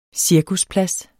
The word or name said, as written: cirkusplads